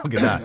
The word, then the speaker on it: Gadot: I don't think it's Godot. I think it's Gal Gadot.